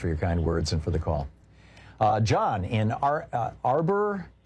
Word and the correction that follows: Auburn: California, am I saying it right? – Auburn. – Yeah, oh, Auburn. Oh, okay.